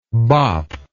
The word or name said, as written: bopp